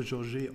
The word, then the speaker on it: George: Äh, ist, äh, ist äh, französisch, also würde ich es äh, Georges aussprechen.